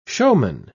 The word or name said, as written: showman